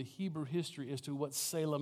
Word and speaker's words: Selah: Selah. S-E-L-A-H. Selah or Salah. However you want to pronounce it, Selah.